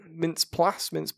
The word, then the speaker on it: Mintz-Plasse: You've got Christopher Mintz-Plasse, I believe is how you say his... – It's Mintz-Plasse, isn't it? – Mintz-Plasse.